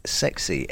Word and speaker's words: XXC: It is Maxine Scott and Alfie, and that is called Sexy, XXC. It's actually written but pronounced sexy.